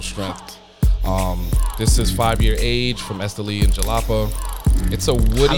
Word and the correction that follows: Jalapa: Jalapa. – Sorry, Jalapa. My Spanish sucks. – J's are pronounced like H's.